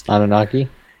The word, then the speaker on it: Anunnaki: If any of you have played, and I'm going to ruin this name, Anukakai Genesis? – Anunnaki? – Yeah, Anunnaki. – Anunnaki. Why has nobody ever pronounced that in my presence like that?